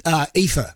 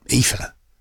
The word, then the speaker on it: Aoife: So Aoife, A-O-E-F-I-A or something like that, Aoife. And that's his wife's name. And my daughter went to school with a girl called Aoife, hence I know the name and how to pronounce it.